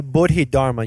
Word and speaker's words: Bodhidharma: Yeah, Bodhidharma.